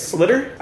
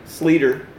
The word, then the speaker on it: Sleater: Is that how you say it? Slitter? – I say Sleater. – Sleater. Sleater. I always wanted to say Slater.